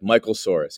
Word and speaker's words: Michael Soares: Like, I also explain it's you pronounce it like a dinosaur, like Michael Soares, because some people are like Suarez.